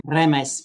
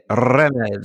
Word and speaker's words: Remes: Remes. – Remes. – I can't do it. – Remes.